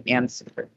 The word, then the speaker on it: answer: For example, why is there a silent W in answer?